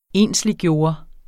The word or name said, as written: ensliggøre